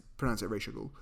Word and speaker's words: Ra's al Ghul: Your pronouncing of Raz is very weird. – I think it is Ra's al Ghul. In the movie, they pronounce it Ra's al Ghul.